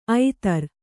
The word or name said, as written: ಐತರು